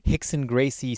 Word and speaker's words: Rickson Gracie: Just look up Hicks and Gracie stomach stretches or exercises, but don't spell it like you would as an American. You got to spell it as a Brazilian. So it's Rickson Gracie. – But all the R's where the H's go.